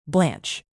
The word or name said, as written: blanch